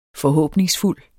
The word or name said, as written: forhåbningsfuld